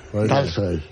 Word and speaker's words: Tolstoy: Porque las palabras que son, las palabras Tolstoy. Que se escriben en Tolstoy. – Las palabras que se escriben en O en ruso, pero van acentuadas, se pronuncian casi A.